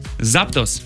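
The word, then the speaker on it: Zapdos: проверил ударение, вроде так. Артикуно, Заптос и Молтрес.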